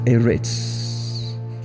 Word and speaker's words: Eretz: Eretz, I think. Eretz. E-R-E-T-Z.